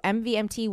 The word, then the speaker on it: MVMT: So movement watches, and it's MVMT, pronounced movement. So MVMT watches,